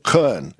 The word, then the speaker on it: Köln: A week from today, I'll be in Cologne, Germany, or as the locals pronounce it, Köln.